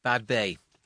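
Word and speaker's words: Badbea: And Tom's album of the week was Edwyn Collins, the new album, Bad Beer. – Bad Bay. – Bad Bay. – Yeah, I was mispronouncing it as well. It's bad. Right. It's a place in Scotland.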